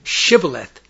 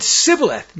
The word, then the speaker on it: Shibboleth: All who wanted to cross the Jordan River at the fords held by the Gileadites were asked to say the word Shibboleth, which means torrent. If anyone said Sibboleth, not pronouncing the SH, his Ephraimite accent gave him away.